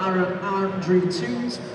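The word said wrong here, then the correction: Tutte: I think he might have said that wrong. – Toot. Yeah, this does say toot there, doesn't it? Tootay. – Tootay.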